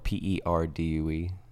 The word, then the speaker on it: Perdue: Just Rick Perdue Music, and my last name is spelled Perdue. – Like the chicken. – Like the chicken, not the university.